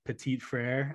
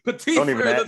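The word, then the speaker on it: Petit-Frere: I'm going to butcher a French accent, but it's like Petit Frere, I think, something along those lines. Yeah. Okay. Yeah, that's the best I got. – Okay. Petit Frere, don't even ask me.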